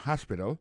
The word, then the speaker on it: hospital: L'hôpital, c'est comme ça on dit ça en anglais, hospital. Alors, accent tombe sur le premier syllabe, il y a trois syllabes, puis she likes to visit hospital. Ensuite, elle a écrit H-O-S-P-I-T-A-L, hospital, l'hôpital.